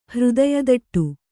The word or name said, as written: ಹೃದಯದಟ್ಟು